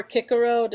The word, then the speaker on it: Cicero: Oh yeah, Cicero or Cicero, depending on if you pronounce it the classical Latin way, was by far one of my favorite uh, statesmen.